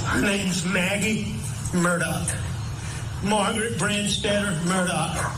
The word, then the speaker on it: Murdaugh: It's very confusing because it's spelled M-U-R-D-A-U-G-H, but apparently it's pronounced Murdaugh.